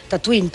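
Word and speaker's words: Tataouine: I de fet, George Lucas i els productors van viatjant per Tunísia, van descobrir un poblet que es deia semblant, pronunciat es deia igual, Tatooine, però s'escriu amb una lletra diferent.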